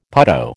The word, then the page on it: putto: American English